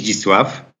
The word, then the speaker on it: Zdzisław: Hi, my name is Zdzisław.